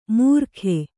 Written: ಮೂರ್ಖೆ

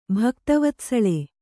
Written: ಭಕ್ತವತ್ಸಳೆ

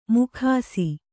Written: ಮೊಗಸು

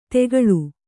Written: ತೆಗಳು

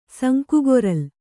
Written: ಸಂಕುಗೊರಲ್

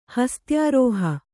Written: ಹಸ್ತ್ಯಾರೋಹ

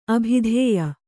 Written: ಅಭಿಧಾಯಿ

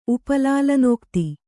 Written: ಉಪಲಾಲನೋಕ್ತಿ

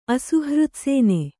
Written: ಅಸುಹೃತ್ಸೇನೆ

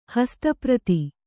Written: ಹಸ್ತಪ್ರತಿ

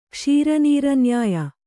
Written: ಕ್ಷೀರನೀರನ್ಯಾಯ

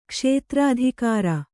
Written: ಕ್ಷೇತ್ರಾಧಿಕಾರ

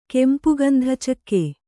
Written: ಕೆಂಪುಗಂಧಚಕ್ಕೆ

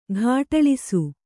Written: ಘಾಟಳಿಸು

